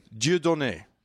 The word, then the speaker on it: Dieudonné: Dudon? – Yeah. – Dudon? – Dieudonné. – Dieudonné. – I believe is how it's pronounced. – Why Dieu? – D-I-E-U. Yeah, like God. Dieu, like Dieu.